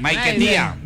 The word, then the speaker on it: Maiquetía: Ella se presentó ahí en el aeropuerto Simón Bolívar de Maiquetía. ¿Lo dije bien? – Maiquetía. – Maiquetía. Pues si lo dije bien, entonces, ¿para qué me corrige? – No lo dijo mal.